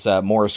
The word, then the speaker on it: Maurice: There's one guy, his name is Morris Gross. It's spelled Maurice, but they call him Morris, which I guess is a British thing.